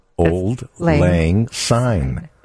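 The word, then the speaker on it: Auld Lang Syne: How do you pronounce it? – Pronounce it. – Old Lang Syne. – Syne? – S-Y-N-E. – Oh, Syne.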